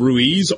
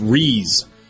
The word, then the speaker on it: Ruiz: We've been calling him Brandon Ruiz, R-U-I-Z. Apparently it's Reese.